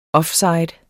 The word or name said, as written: offside